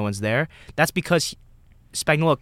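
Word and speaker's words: Spagnuolo: Spagnola.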